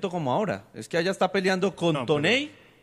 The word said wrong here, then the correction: Toney: O no sé si se pronuncia así. – Tony.